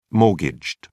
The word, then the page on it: mortgaged: British English